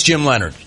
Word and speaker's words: Leonhard: There are a lot of names that are not pronounced the way they are spelled, including Jim Leonhard. – Yes, Jets. – Leonhard. – It's way more difficult than it needs to be. You know it's Jim Leonard.